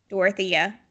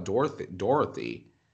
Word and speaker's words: Dorothea: I'll say her name right. – Dorothea. Some people call it Dorothy. – Well. – But I see Thea.